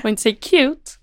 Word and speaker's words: QT: Visst säger cute, vilket jag tycker är väldigt förvirrande. – Ja, men det står här också, uttalas cute. Ja, men så är det, jag har alltid sagt QT.